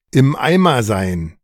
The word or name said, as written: im Eimer sein